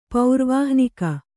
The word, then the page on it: ಪೌರ್ವಾಹ್ನಿಕ: kannada; paurvāhnika